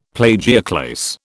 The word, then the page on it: plagioclase: British English